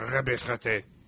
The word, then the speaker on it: Ranbir Sartain: But we are introduced to a new character, Dr. Ranbir Sartain, or as he said, I'm Dr. Ranbir Sartain. – Yes, that's his pronunciation of his own name.